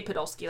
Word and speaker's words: Podolsky: It might either be Podolsky or Podlosky. I'm going to say Podolsky. That's what it's spelled like the majority of the time.